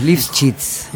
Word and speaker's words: Lifschitz: Vos agarrala ahí, pegale para adelante, metele consonantes, Lifschitz.